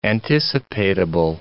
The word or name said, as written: anticipatable